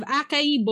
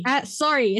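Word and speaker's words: açaí: So they're white, they're skinny, they're rich, they have acai bowls every morning and smoothies in the afternoon. – It's acai.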